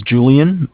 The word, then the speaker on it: Julian: Okay, it's Julian, my name, J-U-L-I-A-N, yep.